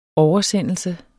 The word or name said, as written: oversendelse